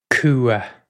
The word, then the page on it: cumha: Irish